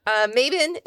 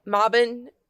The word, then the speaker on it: Mabon: So, Mabin is coming up, or Mobin, depending on how you pronounce it. Mobon is the English pronunciation. Mabin is the American, because we make everything sound ugly.